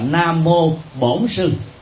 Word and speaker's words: Bổn: Người miền Nam đọc trại âm thành Nhật Bổn, bản thành là bổn. Do đó khi niệm Nam Mô Bản Sư thì người Việt Nam đọc là Nam Mô Bổn Sư, mà có nghĩa đen là gì?